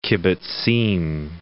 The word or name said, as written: kibbutzim